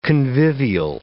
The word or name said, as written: convivial